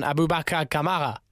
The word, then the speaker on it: Aboubakar Kamara: JS, thank you very much for giving us a bit of a lowdown on Aboubakar Kamara.